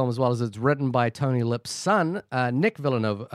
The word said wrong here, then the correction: Vallelonga: Uh, Villanogo. Is that how do you say it? I, I should know this. Vallelonga. Vallelonga.